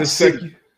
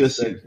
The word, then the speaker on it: Gesicki: I don't want to butcher his last name, but Mike Gesicki. – Yeah, Mike G. What is Mike it? – Gesicki. Yeah, Gesicki.